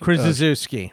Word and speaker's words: Krzyzewski: Krzyzewski. Right. Yes. Okay. – Like that's how it's spelled out, because find a sh in there. There's no sh.